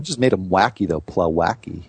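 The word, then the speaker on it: Plawecki: Plowacki or whatever. – I'll go with that guy on the Mets. Plowacki, you said it right the first time.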